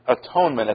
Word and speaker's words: atonement: It used to be pronounced at one ment. Look at the word. At, break the syllable there, one, and then break the syllable there, ment.